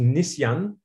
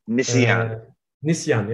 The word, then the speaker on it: Nisyan: And the museum, the exhibition at the museum that you mentioned at the very beginning, the Turkish name, by the way, is pronounced Nisyan. – Nisyan, yeah, exactly.